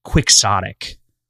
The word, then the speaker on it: quixotic: In English, it is quixotic. Quixotic is how you say it. However, Spanish, it's Quixote, right? So you'd think chaotic, because that would be the combination of the two languages in theory, which is what it's based on in the original context.